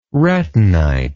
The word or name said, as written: retinite